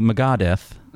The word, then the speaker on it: Megadeth: If he's listening to Megadeth. Did I say it wrong? – Yeah, no, that's fine.